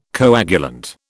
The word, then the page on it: coagulant: British English